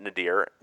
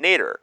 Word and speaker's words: nadir: So, Nadir, for everyone out there, is from an Arabic root word, Nazir, and the British adapted it. They pronounce it Nadir, we pronounce it Nader.